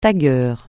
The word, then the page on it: tagueur: francais